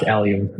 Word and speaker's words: Allium: Yeah, it's pronounced Allium.